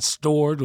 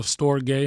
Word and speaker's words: storge: You got stored or storge, how some people pronounce it differently.